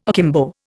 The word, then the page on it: akimbo: Indian